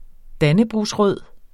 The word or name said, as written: dannebrogsrød